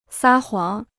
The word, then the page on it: 撒谎: sā huǎng